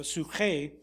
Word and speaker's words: psuche: This is the Greek word psuche, psuche. Or suche is really kind of the proper pronunciation of it, suche.